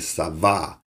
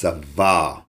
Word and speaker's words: tsavah: And the Hebrew word for command is savah. It's a silent T. It's spelled T-S-A-V-A-H, but it's a silent T. So it's savah.